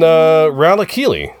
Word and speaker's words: Ralakili: Okay, and then Ralakili, which rhymes with Malakili, but is not Malakili.